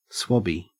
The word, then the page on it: swabby: Australian English